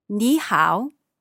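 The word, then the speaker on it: Ni hao (你好): Ni hao. – Ni hao. Ni hao. Okay, what about how are you? – Ni hao ma.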